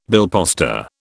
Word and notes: billposter: British English

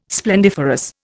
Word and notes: splendiferous: Indian

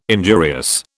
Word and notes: injurious: British English

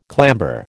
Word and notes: clamber: American English

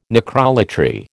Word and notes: necrolatry: American English

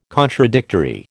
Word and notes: contradictory: American English

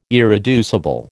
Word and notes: irreducible: American English

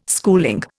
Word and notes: schooling: Indian

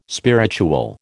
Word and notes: spiritual: American English

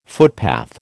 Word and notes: footpath: American English

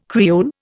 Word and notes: Creole: Indian

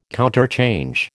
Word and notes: counterchange: American English

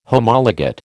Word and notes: Homologate: American English

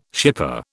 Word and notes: shipper: British English

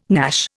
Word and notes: gnash: Indian

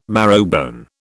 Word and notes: marrowbone: British English

